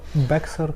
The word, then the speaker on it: Bexar: Bexar County. – Да, но там как-то X не читается, по-моему.